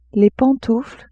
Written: pantoufles

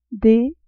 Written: d

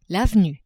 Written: avenue

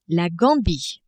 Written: Gambie

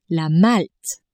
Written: Malte

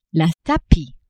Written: tapis

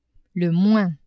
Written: le moins

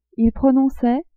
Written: il prononçait

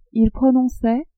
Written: ils prononçaient

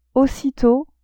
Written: aussitot

